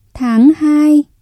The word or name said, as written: tháng hai